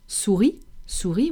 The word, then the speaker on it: souris: Souris, which means mouse, and it's always spelled with an S at the end.